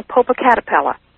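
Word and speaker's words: Popocatépetl: And then the final thing is, does he have any history on Popocatapetl? – Oh, you can pronounce it.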